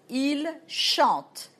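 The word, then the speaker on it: chantent: If you say, for example, il chante in the plural, E-N-T in the end. That's the present of chanter. Il chante. We don't pronounce E-N-T. We don't pronounce E-N-T in French.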